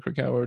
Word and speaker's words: Krakauer: First, how do you know of Dr. David Krakauer? Do you guys work together? How do you know each other? – Yeah. So first of all, he pronounces it Krakauer. – Ah, Krakauer. – Yes, Krakauer. Krakauer.